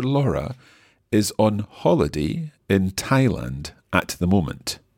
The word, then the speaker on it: Thailand: se, escribe T-H-A-I-L-A-N-D, pero se pronuncia Thailand. – Thailand. – Thai, con T, ¿ok? Thailand. – Thailand. – Muy bien. Ok, normalmente TH como think se, se pronuncia como th, think. – Think. – O como gracias, thank you. – Thank you. – Pero el nombre de este país se dice Thailand.